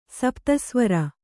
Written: ಸಪ್ತಸ್ವರ

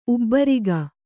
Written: ಉಬ್ಬರಿಕೆ

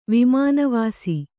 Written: ವಿಮಾನವಾಸಿ

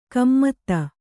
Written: ಗುಮ್ಮತಿ

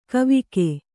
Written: ಕವಿಕೆ

